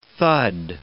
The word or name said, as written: thud